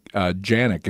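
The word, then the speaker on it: Canik: So he won a really neat Canic Janik is how they pronounce it over there, apparently.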